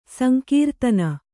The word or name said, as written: ಸಂಕೀರ್ತನ